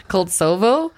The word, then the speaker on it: Koltsovo: This specific research center is called Russia's State Research Center of Virology in the city of Koltsvolv. I can never pronounce anything Russian. – Koltsovo? – Yeah, Koltsovo in Siberia.